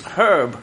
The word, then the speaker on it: Herb: I'm sorry, it's Herb.